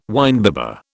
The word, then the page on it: winebibber: British English